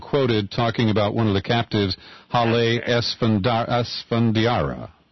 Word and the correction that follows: Esfandiari: How do we pronounce that? – Her name is Hale Esfandiari. – Esfandiari. – Yes,